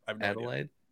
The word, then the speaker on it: Adelaide: Is that how you say it? – Adelaide, Australia.